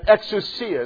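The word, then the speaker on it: exousia (ἐξουσία): And exousia is how it's pronounced in the Greek.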